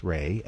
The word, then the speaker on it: Rey: And, you know, his name is pronounced Ray, and it's spelled Ray, R-E-Y, just so you don't get him confused with some other Rays.